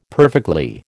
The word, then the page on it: perfectly: American English